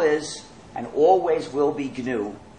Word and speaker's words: GNU: But when it's the name of our system, the correct pronunciation is GNU. Pronounce a hard G.